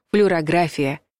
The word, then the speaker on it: флюорография: Запоминайте, корень слова «флюор». Даже если мы букву «о» не произносим, а ударение падает на предпоследний слог — «флюорография».